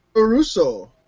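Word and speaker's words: puroresu: And it's spelled like P-U-R-O-S-E-R-R-O. Puro Russo is what it was called. It came about because that was how the Japanese pronounced pro wrestling when they came over to America. They couldn't say pro wrestling. They said, Puro Russo.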